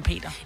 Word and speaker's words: Peder: Han staves Peder, men han kalder sig Peter. – Det var det rigtig mange, der gør. Især når man kommer fra Jylland, så udtaler man det Peter, selvom det er med D.